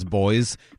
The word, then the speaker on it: Boyce: The other big one is just the way you pronounce boys, B-O-Y-C-E.